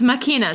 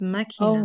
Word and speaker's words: machina: It says machina. Do you see the pronunciation? It says machina.